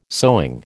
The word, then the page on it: sewing: American English